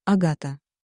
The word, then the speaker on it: Агата: Меня зовут Агата. Да, именно так, с ударением на вторую «а», словно в шипении рассерженной змеи, которая приготовилась к броску.